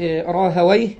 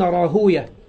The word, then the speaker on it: راهويه: إسحاق أي ابن راهويه، وطبعا راهويه ديت على نطق النحاة، والمحدثين يقولون راهوية، يعني نطق هذه الأسماء المختومة بويه، التي آخرها واو وياء وها، زي خالويه وسيبويه وعمرويه وراهويه، للعلماء فيها نطقان، فبعضهم ينطقها هكذا راهويه، وبعضهم ينطقها راهوية، وهي في النهاية أسماء أعجمية مركبة من مقطعين، مقطع الأول يتغير والثاني ثابت وهو وي، وركبت تركيبا مزجية، فهو علم مزجي.